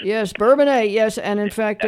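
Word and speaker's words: Bourbonnais: So, well, it used to be Bourbonus, and then we got a French mayor that came into town.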